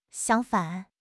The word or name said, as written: xiang1 fan3